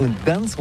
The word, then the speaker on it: Gdańsk: Ça s'écrit G-D-A-N-S-K. On dit Gdansk ou Gdansk, on prononce le G ou pas?